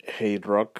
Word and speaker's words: HTRK: Y luego continuamos con Hate Rock. Así se pronuncia, pero se escribe medio raro como HTRK.